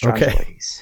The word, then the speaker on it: Strongyloides: specifically it's Strongyloides, right? Or did I butcher the pronunciation again? – Nope, you're perfect. Strongyloides. Okay.